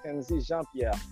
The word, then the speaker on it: Kenzy Jean Pierre: But my name is Kenzy Jean Pierre. I know it's difficult to pronounce, but you know, Jean Pierre is French, and I know it's difficult.